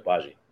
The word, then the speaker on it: Agbaji: You're saying it right, Agbaji.